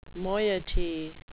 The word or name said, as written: moiety